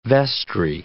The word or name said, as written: vestry